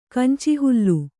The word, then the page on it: ಕಂಚಿಹುಲ್ಲು: Kannada